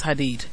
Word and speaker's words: Hadid: It's Hadid. – Look, sometimes did, sometimes deed. – It's never did. It's deed. Hadid.